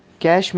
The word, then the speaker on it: cache: Cache memory, pronounced as cache, the cache, a special high-speed storage mechanism.